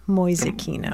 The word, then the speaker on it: Mäusekino: M-A-U-S-E-K-I-N-O. Mäusekino.